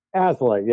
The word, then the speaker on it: Aslay: Aslay, yeah.